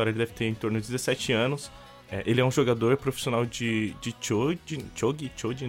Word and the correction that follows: shogi: não sei como pronuncia direito. Tanto que até o anime Shogi. – É meio... Shogi, muito obrigado, Hawk.